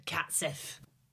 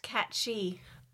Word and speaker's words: cat sìth: Ah, the cat Sith. – Actually, it's Gaelic and it's pronounced cat she.